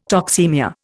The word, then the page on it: toxaemia: Indian